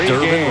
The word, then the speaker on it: Durbin: You know Durbin rhymes with bourbon? – Hell yeah, I love bourbon.